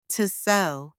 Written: to sew

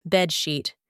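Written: bed sheet

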